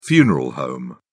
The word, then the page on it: funeral home: British English